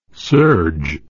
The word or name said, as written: surge